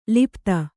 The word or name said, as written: ಲುಪ್ತ